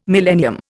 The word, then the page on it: millennium: Indian